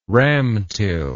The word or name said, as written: ramtil